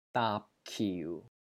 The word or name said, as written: DAAP3 KIU4